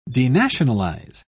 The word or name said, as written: denationalize